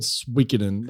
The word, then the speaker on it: Suikoden: But for the love of God, the game is called Suikoden. That's how it's pronounced.